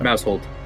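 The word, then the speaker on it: Maushold: Is it Mousehold? Is that how you pronounce it? – Oh, yes, yeah. Mousehold. – Like a household. – It's a household of mouse.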